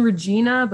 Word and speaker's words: Regina: Regina. Like Queen Regina, but it's got it. It's pronounced Regina.